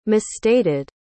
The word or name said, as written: misstated